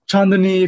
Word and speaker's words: Chandani: So our last name is Chandani, uh, obviously pronounced Chandani if you like saying the word, but we oh, say yeah, Chandani.